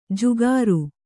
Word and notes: ಜುಗಾರು: jugāru

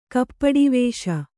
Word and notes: ಕಪಟವೇಷ: kapaṭa vēṣa

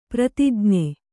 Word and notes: ಪ್ರತಿಜ್ಞೆ: pratijñe

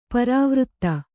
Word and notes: ಪರಿವೃತ್ತಿ: parivřtti

